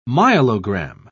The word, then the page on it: myelogram: British English